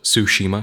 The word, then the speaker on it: Tsushima: Doom Eternal, Ghost of Tsushima. Sorry, I mispronounced that. It's Ghost of Tsushima, because it's like tsunami, the T is silent.